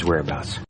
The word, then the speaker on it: whereabouts: We need his whereabouts. – And that whereabouts, unknown. – The pronunciation, it's pretty straightforward. There are three syllables. The stress is on that first syllable, whereabouts. Let's try and do a little bit of review.